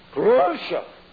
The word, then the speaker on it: Khrushchev: Is that the way you pronounce him? The past one? I'm trying to say it like a Russian. Khrushchev.